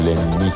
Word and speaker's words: Лянни: Квартира принадлежала его другу, манекенщику Лянни. С ударением на «и», уточнил он.